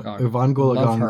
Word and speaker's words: Evonne Goolagong: Oh, uh, Ivan if, Evonne Goolagong did it.